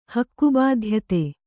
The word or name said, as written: ಹಕ್ಕುಬಾಧ್ಯತೆ